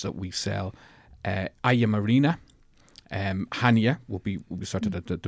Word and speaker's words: Chania: The flight, it's Ryanair fly into Hania, or Chania as it's written, but it's pronounced Hania.